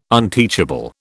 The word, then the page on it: unteachable: British English